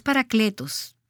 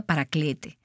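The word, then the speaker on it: paracletos (παράκλητος): La palabra en el idioma griego es paracletos. Tal vez has oído que se pronuncia paraclete.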